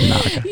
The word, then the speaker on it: Naga: Naga, as they say. – Naga, right? Is that what they say? – Yeah, that's how you say it.